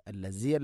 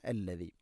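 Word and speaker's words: അല്ലദീ (الذي): ഹുവല്ലാഹു അല്ലദീ ഹുവല്ലാഹു അല്ലദീ അല്ലദീ, ദാലിൻ്റെ ഉച്ചാരണം ശ്രദ്ധിക്കുക. അല്ലസി അല്ല അല്ലദീ.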